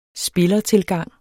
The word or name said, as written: spillertilgang